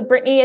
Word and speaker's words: Brittany: So Brittany is spelled BRITTANY.